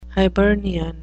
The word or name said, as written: hibernian